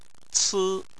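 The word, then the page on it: ci1: tone 1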